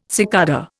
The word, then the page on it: cicada: Indian